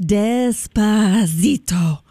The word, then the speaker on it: Despacito: I think it's pronounced Despacito. I think you got to really elongate that, just so you know.